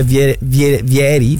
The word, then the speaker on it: Vieri: È vero che c'è Vieri, senza accento sulla I, perché non è francese, no?